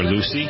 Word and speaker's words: Luce: It's spelled L-U-C-E. – Luce. Really? – Or Lucy.